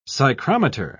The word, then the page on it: psychrometer: British English